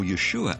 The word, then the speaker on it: Yeshua: The word Jesus, or Yeshua as it's pronounced in Hebrew, means God's salvation or God's Savior.